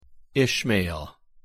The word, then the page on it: ishmael: English